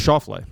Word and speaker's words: Schauffele: was it Schauffele? How do you pronounce his name? – Xander Schauffele. – Yeah, Schauffele.